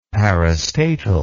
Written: parastatal

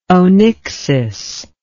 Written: onyxis

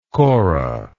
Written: korah

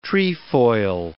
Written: trefoil

